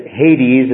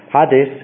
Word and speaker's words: Hades: In the New Testament, you see it as as Hades, as we pronounce it in English, actually Hades in the Greek.